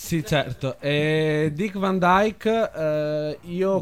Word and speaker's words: Van Dyke: Van Dyke. Beh, insomma, noi lo pronunciamo così. – Dick Van Dyke, giustamente. Il nostro Bert ha detto giusto.